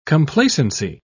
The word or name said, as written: complacency